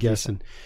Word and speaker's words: Nguessan: And Bamisil is earning, David Nguessan. – Yeah, and David, I think the N is silent. – I think you're right. – David Guessin. He's a 6'8", 6'9", forward.